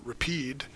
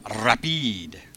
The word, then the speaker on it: Rapide: You have to roll the R, Rapide. There's no accent aigu at the end, so it's not rapide. So I think it's rapide.